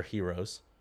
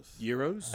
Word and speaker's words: gyros: It's either Gyros or Heroes. – Euros? – I think it's regional. – Yeah. – You say it how you say it. – Just depends on what give you, me how a you want to say. – Depends on where you're from.